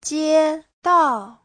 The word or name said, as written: jie1dao4